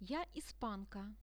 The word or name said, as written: ja ispanka